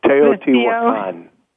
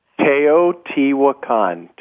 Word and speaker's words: Teotihuacan: Teotihuacan. – Say that again? – Teotihuacan.